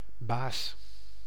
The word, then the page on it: chief: /tʃiːf/